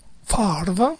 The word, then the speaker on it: Favre: Alla 17 ci sono i Jets che andranno su un quarterback perché finalmente Favre è andato Favre, perché la pronuncia va invertita, la R con la V.